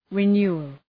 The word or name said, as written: renewal